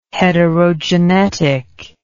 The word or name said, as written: heterogenetic